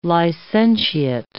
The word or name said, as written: licentiate